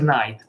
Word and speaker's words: Knight: Darkness della Top Course, Darkness è quello, ci sono due videogiochi, Moon Knight, Moon Knight, l'ho scritto così, si pronuncia Knight, però la K è silenziosa, come, come la, la D di Django.